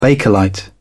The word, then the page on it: bakelite: British English